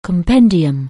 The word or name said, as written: compendium